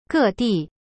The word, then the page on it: 各地: ge di